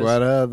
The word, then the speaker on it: Guetta: Yeah, Guetta, that musician.